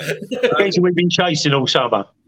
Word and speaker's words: Nketiah: You know, Nikita, I think the scariest moment for me in the first half was when Nikita turned Anderson and he was through on goal, but Anderson didn't. Nketiah, not Nikita. Nikita's Elton John's song.